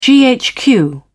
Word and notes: ghq: British English

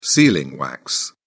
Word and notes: sealing wax: British English

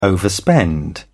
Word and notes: overspend: British English

